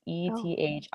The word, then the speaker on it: Ethique: It's French for ethical. It's Ethique. But I love that brand.